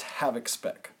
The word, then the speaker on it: Havixbeck: It's pronounced Havixbeck.